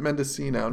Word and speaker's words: Mendicino: No, but I'm told people pronounce it Mendicino. Not really? – Mendicino. You don't do the hard C.